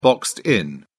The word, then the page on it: boxed in: British English